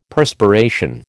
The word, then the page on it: Perspiration: American English